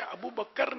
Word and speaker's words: ابوبکر: اور ابو بکر یہ پہلے بھی میں کافی سمجھا چکا ہوں کہ یہ تلفظ غلط ہے. ابو بکر نہیں ہے, ابو بکر کاف ساکن ہے.